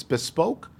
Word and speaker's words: Bespoke: I think it's Bespoke. – Yes.